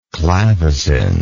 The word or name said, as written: clavacin